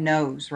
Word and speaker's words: KNOSE: How do you say that? Nose, right? K-N-O-S-E. – K-N-O-S-E. Nose.